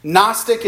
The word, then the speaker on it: Gnostic: Gnostic is spelled G-N-O-S-T-I-C, Gnostic.